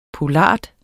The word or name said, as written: poulard